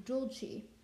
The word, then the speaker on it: dulci: So, like, the word dulci, which, like, think dulce de leche, so D-U-L, but with an I instead, so D-U-L-C-I. In singing Latin, is pronounced in dulci, but in speaking Latin, it's pronounced in dulci.